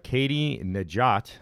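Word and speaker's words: Katie Nageotte: So, in other sports news, local sports news, Katie Najat, I believe I'm pronouncing that right. – Yep.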